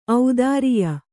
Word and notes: ಔದಾರಿಯ: audāriya

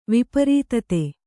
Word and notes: ವಿಪರೀತತೆ: viparītate

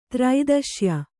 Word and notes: ತ್ರೈದಶ್ಯ: Kannada

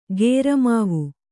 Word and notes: ಕುರಮಾವು: kuramāvu

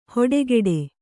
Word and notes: ಹೊಡೆಕೆಡೆ: hoḍe keḍe

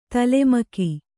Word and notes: ತಲೆಮಗ: tale maa

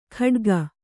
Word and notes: ಖಡ್ಗ: khaḍga

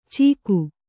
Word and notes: ಚೀಕು: Kannada

